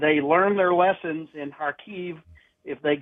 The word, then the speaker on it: Kharkiv: It is in Kharkiv, or as they pronounce it, Kharkiv.